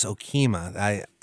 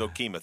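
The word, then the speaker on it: Okemah: Yeah, and it's pronounced Okima. You don't want to make that mistake in town. – I will never, ever again say Okama. It's Okima.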